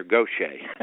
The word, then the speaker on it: Gautier: And for the audience, that is G-A-U-T-I-E-R. And we pronounce that down here, Goshen.